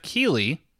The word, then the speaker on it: Keighley: I'll probably mispronounce this name, but after Jeff Keighley... – You nailed it.